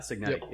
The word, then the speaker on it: Cignetti: Am I saying his name right? Cignetti? – Yeah, Cignetti. Yep.